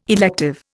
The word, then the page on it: elective: Indian